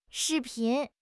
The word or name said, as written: 视频